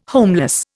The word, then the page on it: homeless: Indian